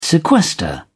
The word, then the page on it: sequester: British English